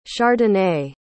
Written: chardonnay

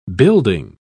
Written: building

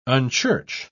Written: unchurch

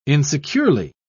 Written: insecurely

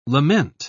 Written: lament

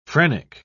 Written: phrenic

